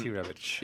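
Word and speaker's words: Rebić: I mean, when you look at the five guys that have Brozovic with, you know, as you said, Rebic, I can never say his name right. Put an itch at the end and you're good. – Rebic.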